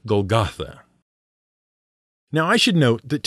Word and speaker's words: Golgotha: The Greek pronunciation is Golgotha.